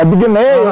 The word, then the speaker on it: Abagnale: Ah, não é assim não. Fala, o burrão. – Então, como é que fala, bonitão? – É Big Nail. Ah, cara. É Big Nail. – Então é Big Nail, tá bom.